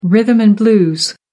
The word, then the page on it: rhythm and blues: American English